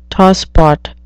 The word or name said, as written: tosspot